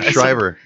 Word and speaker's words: Shriver: Shriver.